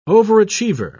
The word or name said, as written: overachiever